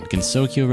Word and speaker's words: Gensokyo: But even if you don't, let me jog your memory here by playing the song I Want to Be the Kyoto on Gensoki Radio Live number 69. – Oh, This is Gensokyo Live number 69. Did I say that right? Gensokyo Radio Live number 69 with Mystery Parfait as well as the usual suspects.